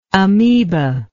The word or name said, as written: ameba